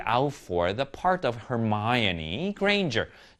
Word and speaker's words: Hermione: 누구의 역할이냐 하면 of Hermione, 헤르미온느라고 우리말로는 읽었지만 Hermione라고 읽습니다. Hermione Granger, 헤르미온느 역의 역할의 시험을 봤습니다.